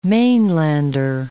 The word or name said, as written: mainlander